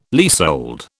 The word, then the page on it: leasehold: British English